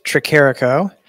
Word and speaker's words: Tricarico: Pastor Al Tricarico, that is how you pronounce it, Tricarico, not Tricarico or anything like that.